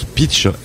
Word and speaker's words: Pitxe: pitch, comme il est écrit là, c'est P-I-T-X-E, mais en basque, on prononce pitch, mais à la base, ça s'écrivait P-I-T-C-H, qui est un terme de golf.